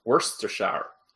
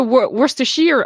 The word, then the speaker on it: Worcestershire: Ça se prononce Worcestershire. – Ça, là. – Ah oui? – T'es supposé tout comprimer. – Mm-hmm. Ah oui? – Ouais. – Je savais pas ça. – C'est pas Worcestershire, c'est Worcestershire.